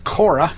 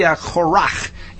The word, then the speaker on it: Korach: And it's actually, it's got the chet on the end, rather than the way most English speakers pronounce it, Korah. But it's actually Korach in the Hebrew.